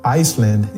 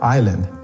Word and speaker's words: island: It was my turn to read it in class, and I pronounced the word Iceland instead of island.